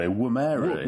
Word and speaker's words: Woomera: we've got to get this pronunciation right, haven't we? Woomera. – Oh, we had it corrected as well, didn't we? Woomera, is it? – Woomera.